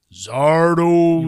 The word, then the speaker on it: Zardoz: Zardoz. – You might need no dose to watch Zardoz. Dawes.